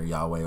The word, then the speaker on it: Yahweh: The Tetragrammaton, the YHWH, which they pronounce either Yahweh or Jehovah, means I am, but it is God.